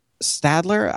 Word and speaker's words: Staedtler: But Staedtler, I think it's pronounced Staedtler. Staedtler, Staedtler. It's a German company.